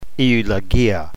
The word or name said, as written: eulogia